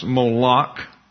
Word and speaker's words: Molech: The word there for king is Molech, pronounced Moloch, as it comes into the English, and Kayun.